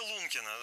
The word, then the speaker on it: Лункина: Деревня Лункина. Вообще, правильно ли я ставлю ударение, вообще сразу возникает вопрос? – Да, конечно. В целом, мне кажется, что такое место и говорящее название, что вы прям... сложно ошибиться, но вы все правильно сказали.